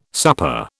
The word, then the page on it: supper: British English